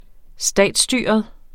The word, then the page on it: statsstyret: -ˌsdyˀʌð; -ˌsdyːʌð